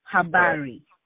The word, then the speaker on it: Habari: Habari. – Habari. – You got it. – All right. – You're pronouncing it as if you're a native Swahili speaker.